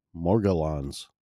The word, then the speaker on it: Morgellons: When you go look up on the artificial intelligence and like, how do you pronounce Morgellons? And they come back and they say Morgellons every time.